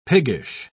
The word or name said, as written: piggish